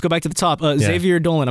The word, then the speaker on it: Xavier Dolan: Okay, so the list we have is Xavier Dolan.